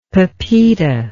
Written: pepita

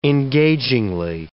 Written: engagingly

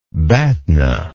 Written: batna

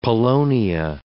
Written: Polonia

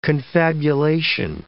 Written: confabulation

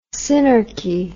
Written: synarchy

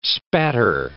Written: spatter

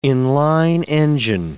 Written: in-line engine

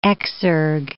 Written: exergue

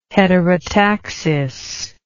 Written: heterotaxis

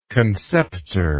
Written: conceptor

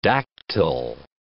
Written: dactyl

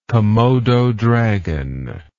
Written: Komodo dragon

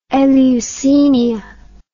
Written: Eleusinia

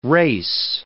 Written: res

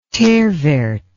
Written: terre verte

